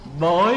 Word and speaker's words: bội: Chữ bội cũng đọc là vối, bối hay là bội cũng một nghĩa thôi, mà đọc âm khác.